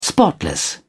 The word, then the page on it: spotless: British English